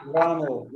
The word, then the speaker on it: Wamel: Is it is it is it Wamel? Is that how you pronounce it? – Very good, Derek. You've uh, you've excelled again. Uh, even the, that pronunciation uh, was better than uh, the, the, the, Dutch shirt. so very good, Wamel. Yeah, excellent.